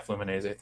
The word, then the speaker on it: Fluminense: Yeah, Fluminense.